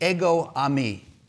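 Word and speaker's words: Ego ami (ἐγώ εἰμι): Ego ami is how it's pronounced, and it basically means the same thing, I am that I am.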